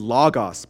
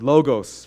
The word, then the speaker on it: Logos: The reason is our church is called Logos. It's actually supposed to be Logos, but it's Logos for the way we pronounce it.